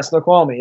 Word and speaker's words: Snoqualmie: Snoqualmie, did I say it right? – Yeah, Snoqualmie, yep.